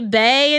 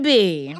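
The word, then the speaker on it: Bey: One of my favorite ways to tell that somebody on Twitter doesn't actually know any black people in real life is how they pronounce the shortened version of Beyonce's name. – Oh. – If they say bae instead of bee.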